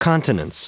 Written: continence

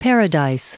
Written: paradise

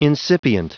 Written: incipient

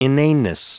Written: inaneness

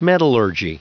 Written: metallurgy

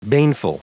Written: baneful

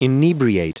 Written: inebriate

